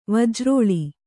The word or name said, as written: ವಜ್ರೋಳಿ